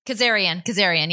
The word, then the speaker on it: Kazarian: How do you say, is that Kazarian? – Kazarian.